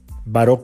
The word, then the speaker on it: Baró: Baró con B grande, así como Teresa Baró, B-A-R, acento en la O, La Gran Guía del Lenguaje No Verbal.